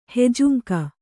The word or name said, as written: ಹೆಜುಂಕ